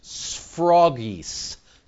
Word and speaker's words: sphragis: Sounds like froggy, doesn't it? Froggy. Froggies. But it's the Greek word pronounced sphragis.